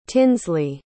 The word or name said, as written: tinsley